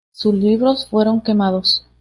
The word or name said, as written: quemados